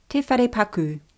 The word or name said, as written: te wharepaku